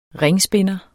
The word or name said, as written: ringspinder